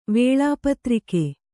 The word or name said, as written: ವೇಳಾಪತ್ರಿಕೆ